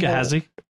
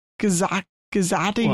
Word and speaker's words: Gehazi: Gehazi? – Yeah, he like, Gazati or something.